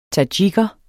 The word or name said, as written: tadsjiker